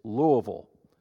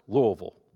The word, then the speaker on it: Louisville: It's Louisville. It's 1.5 words or syllables, Louisville.